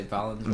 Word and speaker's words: Valenzuela: Did I pronounce that right? Valenzuela? – I usually say Valenzuela.